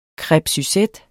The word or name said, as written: crepe